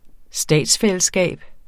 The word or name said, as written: statsfællesskab